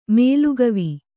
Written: ಮೊಲಕಿವಿ

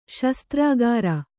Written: ಶಾಸ್ತ್ರಕಾರ

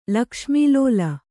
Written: ಲಕ್ಷ್ಮೀಲೋಲ

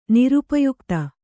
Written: ನಿರುಪಯುಕ್ತ

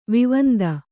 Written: ವಿವಂದ

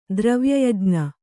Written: ದ್ರವ್ಯಯಜ್ಞ

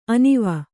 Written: ಅನುವು

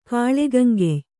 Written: ಕಾಳೆಗಂಗೆಯ್

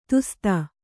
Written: ದೋಸ್ತ

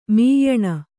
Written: ಮ್ಯಾನಾ

